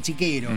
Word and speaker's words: Cichero: Así que del chiste de da para todo, que se llama Cichero, en italiano se pronuncia Chiquero.